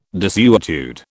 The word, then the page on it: desuetude: British English